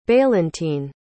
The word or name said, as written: bailintin